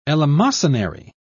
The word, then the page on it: eleemosynary: British English